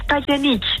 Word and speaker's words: Titanic: Nou, het is Titanic. Het is zeg maar hoe Celine het op zijn Engels met haar Franse accent zou zeggen. Dus Titanic.